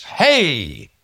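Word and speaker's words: hey (ה): It's hey. H-E-I. So you just go, hey. That's how you pronounce it.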